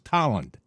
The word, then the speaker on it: Tolland: So we all, in Connecticut, T-O-L-L-A-N-D, right? Yeah, I pronounced it Toland. No, it's Toland. Toland. – It's like chowder is chowder. – Hey, how about in Connecticut, B-E-R-L-I-N?